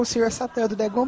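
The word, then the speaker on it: Šatan: Tinha um jogador tchecoslováquio que o sobrenome dele pronunciado era Chatan, só que a pronúncia era Satan, S-A-T-A-N.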